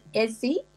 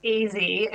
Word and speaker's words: Ezzy: One of the other stories that was quite delightful was how you highlighted, is it Ezzy? Is that how you pronounce it? Ezzy Moses's legendary spice shop in Bondi. – So it's pronounced Easy, as in it's short for Ezekiel.